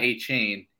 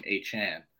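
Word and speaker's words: Achane: That guy, Achan, it's not Achan. He said on Twitter to pronounce his name, Achan.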